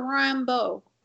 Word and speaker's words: Rambeau: You know, is it Rambeau or Rambo? – Rambo, sorry. Sorry.